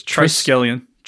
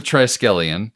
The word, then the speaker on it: Triskelion: Triskelion. – Triskelion? – Yep. – Okay. So we're back at the Triskelion.